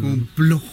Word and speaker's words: complot: Así, con la O al final, con complot.